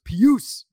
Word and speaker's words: Pius: It's pronounced Pius.